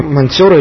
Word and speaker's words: монтёры: это были какие-то монтёры или что-то в этом духе. – В традиции принято ударение на «о», монтёры.